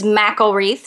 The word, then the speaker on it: McElreath: We had Kelley McElreath on last week, and I kept pronouncing her name incorrectly. So it is McElreath, so I want to say that.